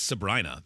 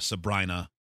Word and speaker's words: Sabrina: I think it's Sabrina, though, because I remember reading somebody said that the pop singer rhymes with vagina. Sabrina.